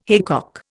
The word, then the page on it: haycock: Indian